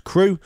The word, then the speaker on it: CHRU: WXYC would like you to know about the Carolina Harm Reduction Union, known as CHRU, pronounced as CRU, which is a student-led program that provides life-saving harm reduction resources to the public of Chapel Hill.